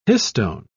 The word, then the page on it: histone: British English